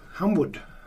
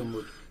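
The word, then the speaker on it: Humewood: Den heter Humble, Humwood. Humwood. Humwood. Jag skulle också säga Humwood.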